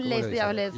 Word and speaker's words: Les Diablets: No lo pronuncian la S, ¿no? Le o les. – Es Les Diablets. – Les. – Sí, les Diablets. Sí, porque no está. No lo decimos francés, lo decimos argentino.